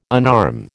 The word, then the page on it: unarm: American English